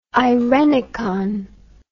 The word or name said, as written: eirenicon